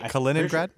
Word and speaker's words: Kaliningrad: Putin's next serious love interest, who became his wife, was a flight attendant from Kaliningrad. What is it? Did I say that right? – I think Kaliningrad.